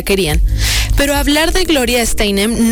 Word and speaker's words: Steinem: Este jueves se confirmó la muerte de Gloria Steinem, periodista, escritora y una de las figuras más influyentes del movimiento feminista contemporáneo.